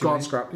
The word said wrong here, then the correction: Grant Shapps: yeah, Grant Shapps, yeah, I can't say his name.